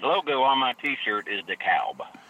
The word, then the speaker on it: DeKalb: Well, there's a P in pneumonia and a K in knife, so you don't pronounce those either. – No, I don't think we pronounce those either. – Checkmate. – No, it's DeKalb.